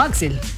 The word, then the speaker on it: Axl: Porque es AXL. – Exactamente. – Pero se pronuncia como Axl.